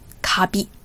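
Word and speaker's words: Kabi (カビ): Kabi. Nicht Kirby, ja, das spricht man auf Japanisch Kabi aus, also langes A.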